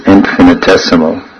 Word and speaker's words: infinitesimal: God is infinite and the living entity is infinite sima. And I believe that the so-called standard pronunciation of that word is infinitesimal. Is that right? Infinitesimal. many words like that.